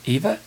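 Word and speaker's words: Aoibhe: Eva? – How do you get that? He's Irish, isn't he? Yes. It's A-O-I-B-H-E, and it's pronounced Eva.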